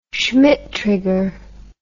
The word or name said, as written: Schmitt trigger